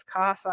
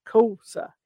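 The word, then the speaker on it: Kosa: Mine is a K-O-S-A, right? So it's CASA. I got all these variations when it was actually COSA.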